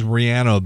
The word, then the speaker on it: Rihanna: And it is Rihanna, by the way. We've been saying it wrong all these years. We've been saying Rihanna. That's wrong.